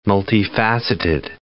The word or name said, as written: multifaceted